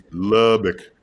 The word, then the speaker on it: Lubbock: You didn't say it right. It's Lubbock. You got to say it right. Lubbock.